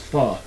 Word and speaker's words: pho: No, that's a dish. It's Pho. It's Pho? – Pho. It's spelled Pho, but pronounced Pho.